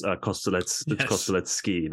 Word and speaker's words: Kostelecki: Just so you know, it's Kostelecki, not Key. – Kostelecki, is it? – Yeah. – Have I been saying that wrong the whole time? – Probably have, but it's fine. Many people get it wrong. – Kostelecki. Let's ski. – Kostelecki. Yeah, Kostelecki, like the coffee shop, and then let's ski, like let's go skiing.